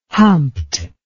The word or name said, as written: humped